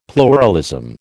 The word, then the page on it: pluralism: American English